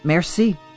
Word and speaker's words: merci: And how do you say thank you in Persian? Merci. Merci. Well, that's an easy one. – Yes, it's an easy one. It's just like French.